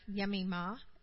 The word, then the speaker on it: Jemima: And the first one was Jemima, or Yemima, as they pronounced it.